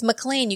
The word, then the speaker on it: McClane: Alex, I have to stop you for a second because you're pulling a Kadish and you keep pronouncing his name wrong and it's making me crazy. – It's not McClane? – It's McClane. You keep saying McCain. – Do I say McCain? – You do. You're saying John McCain.